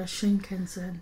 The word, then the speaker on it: Shinkansen: One morning we got on the Shinkansen. Did I say that right? Which is the bullet train. – Yeah, the bullet train. Yeah, you said it right.